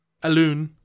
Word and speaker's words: Alun: You didn't mention Alan. – No. No, I mentioned Wynn Jones. – Alun, as it's spelled.